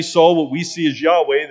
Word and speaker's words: Yahweh: which is we show it with the YHWH, Yahweh is how we would pronounce it.